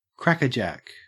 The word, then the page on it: crackerjack: Australian English